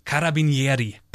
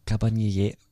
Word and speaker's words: Carabinieri: Cabanieri. ich habe die ganze Zeit geübt. Carabinieri. Carabinieri. Ich kann es nicht sagen. Das ist doch. – Cara. Carabinieri.